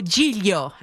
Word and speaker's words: Giglio: Joe Giglio, that's how you say it in Italian, Joe Giglio, comes your way at 1 o'clock.